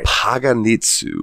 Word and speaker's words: Paganitzu: Paganitzu, which I think I'm saying that right. – That is how I'm pronouncing it in my head as well.